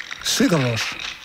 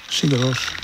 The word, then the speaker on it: Sigur Rós: you don't just say Puccini on here, you know. You give it loads. So it's Sigur Rós. Sigur Rós.